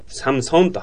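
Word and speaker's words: Samsung: だからサムソンもサムソンというふうに U じゃないの？英語表記だと。でもこれは U だから U の音だと思って、我々サムスンとかって言っちゃったりするんだけど、これは韓国語としてはサムソンと発音して欲しかったものなんですよ。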